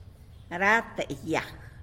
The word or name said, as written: rata yag